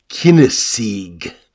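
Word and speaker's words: Cynesige: is it would have been pronounced perhaps something like Kinesig.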